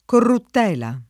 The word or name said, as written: corruttela